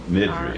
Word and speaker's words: Nydrie: It's spelled Nydrie. Nidri.